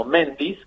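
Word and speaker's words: Mendiz: Bueno, nosotros lo usamos como Méndiz, como tal cual vos lo pronunciaste, aunque en realidad creo que literalmente se debiera pronunciar Méndiz.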